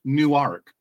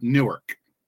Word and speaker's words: Newark: It's pronounced Newark, not Newark. That was one of the funniest things when I lived in Memphis.